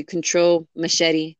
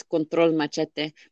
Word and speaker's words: Control Machete: So, like, if you were to pronounce it in English, it'd be Control Machete, but in Spanish, it's Control Machete.